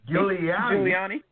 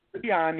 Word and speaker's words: Giuliani: Next, besides farting during an election hearing, Rudy, and somebody say his last name for me, Giuliani. I'm trying to say it like Joy. Giuliani. – Giuliani.